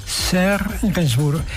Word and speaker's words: Serge Gainsbourg: ¿Lo he pronunciado bien? Serge Gainsbourg, perfecto.